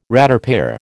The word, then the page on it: ratepayer: American English